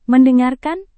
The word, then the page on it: mendengarkan: Indonesian